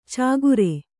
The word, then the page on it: ಚಾಗುರೆ: cāgure